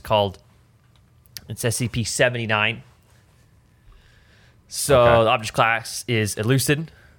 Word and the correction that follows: Euclid: or how do I pronounce that? Actually, let's see. Euclid, actually. E-U-C-L-I-D, Euclid, which is a dangerous thing, right?